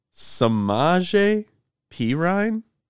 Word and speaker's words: Samaje Perine: Is that how you say his name? Is that really? – Yes. – That's correct? – Yes. – Samaje Perine? You're serious? – Yeah. – Like you heard somebody say it and they were, yes. They said it correctly.